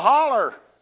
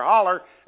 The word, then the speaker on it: hollow: That's what we say here, holler. H-O-L-L-O-W, holler. I had a guy from California one time said, how do you spell that? I said, H-O-L-L-O-W, holler. He said, that spells hollow. I said, I know, but here hollow means empty. We call them hollers.